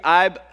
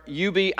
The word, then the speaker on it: Ub: If I said the name to you, IB, Iwerks or UB Iwerks, eh, there's no consensus on even, eh, I love this. There's no consensus on how to properly pronounce this man's name.